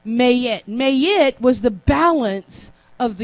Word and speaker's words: Maat: Because if Mayet, M-A-A-T, but it's pronounced May-it,